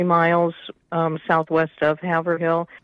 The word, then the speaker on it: Haverhill: I'm calling you in Haver Hill, Iowa, correct? – Yeah, it's actually pronounced Haverhill.